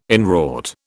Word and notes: inwrought: British English

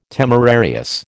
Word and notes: temerarious: American English

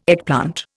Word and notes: eggplant: Indian

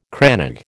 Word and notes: crannog: American English

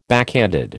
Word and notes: backhanded: American English

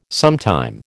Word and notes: sometime: American English

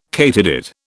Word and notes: katydid: British English

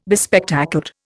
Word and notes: bespectacled: Indian

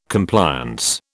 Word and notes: Compliance: British English

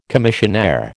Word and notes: commissionaire: American English

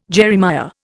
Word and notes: Jeremiah: Indian

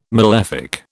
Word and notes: malefic: British English

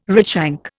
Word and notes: redshank: Indian

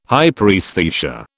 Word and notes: hyperaesthesia: British English

